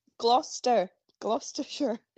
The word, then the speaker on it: Gloucestershire: Gloucestershire.